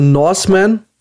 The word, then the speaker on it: Norseman: Äh, also Norseman, äh, das ist wirklich Norse mit N-O-R-S-E, nicht North falsch ausgesprochen.